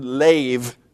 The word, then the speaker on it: lev: And I remind you again that this Hebrew word lev, L long E, V, 50% of the time it's your feeler, your seat of emotions, and the seat of the will.